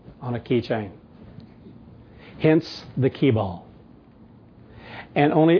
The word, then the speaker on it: Kibal: I'm pretty sure they pronounce it Kibal. It's K-I-B-A-L, or Kibal.